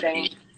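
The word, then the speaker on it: Bene: Like, in current artist wise, that artist Benny is really good. I don't know how to pronounce her name. – B-E-N-E. I said Benny. Benny? Okay.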